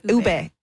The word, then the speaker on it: ube: I'm going to ask a volunteer to come into the studio and try, am I saying it correctly? Ube? – Ube. – Ube? – Ube. – Ube. Yeah. Okay, ube.